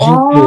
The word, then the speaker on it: 沈香: 人工。 – ああ、人工。チンじゃないって、ジンですね。 – 人工ですね。 – うん。 – これ香りが有名なんですけど、漢方だと飲んだりもします。 – へえ。 – ただ、そのまま服用するともったいないので、人工が入っている、漢方の場合は、その香りを嗅いでから飲んでくださいって言うんですよ。